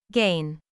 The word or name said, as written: gain